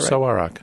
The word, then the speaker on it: Sarawak: Christopher from Sarawak, Malaysia. Does that sound right? Sarawak? – Yeah. – Did I say that right? – Sarawak.